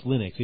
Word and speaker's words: Linux: Well, first of all, it's pronounced Linux, even though Fiber and I disagree on this. – Yeah, I disagree because the guy's name is Linus. – Yeah, I know, but I read in the book it's supposed to rhyme with, what is it, Minix? or whatever that operating system is.